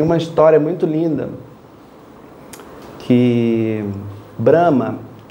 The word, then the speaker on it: Brahma: Em sânscrito, a gente pronuncia Brahma com o A longo.